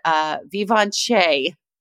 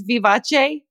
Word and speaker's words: Vivace: Well, there's another one that's called Vivace. Can't be that. It's Vivace. V-I-V-A-C-E. They all pronounced it like really fancy.